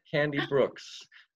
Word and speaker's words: Candi Brooks: I'm here with Candi Brooks. Is that how you pronounce it properly? – Yeah, that's it. – Candi Brooks.